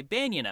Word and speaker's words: banyan: I think I called it banyan, or something similar to that. Apparently, it is more commonly pronounced banyan, so I'm switching how I'm saying it so I sound more normal.